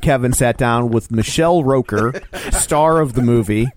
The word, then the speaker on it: Rooker: Michael Rooker, or if you're Joe, Michael Rocker. How did you say it? – I said Rooker.